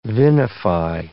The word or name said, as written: vinify